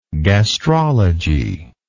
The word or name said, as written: gastrologist